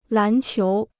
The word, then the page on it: 篮球: lán qiú